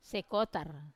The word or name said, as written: sekotar